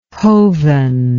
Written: hoven

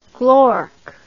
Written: glork

